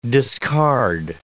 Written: discard

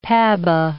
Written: PABA

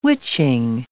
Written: witching

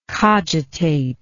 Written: cogitator

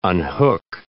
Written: unhook

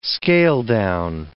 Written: scale-down

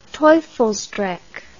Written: teufelsdrockh

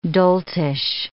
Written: doltish

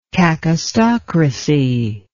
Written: kakistocracy

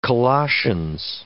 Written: Colossians

